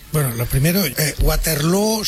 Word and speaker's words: Waterloo: Pues no lo es, desde luego, pero Ava ha hecho mucho daño en la pronunciación de Waterloo, que se pronuncia igual que Feijóo.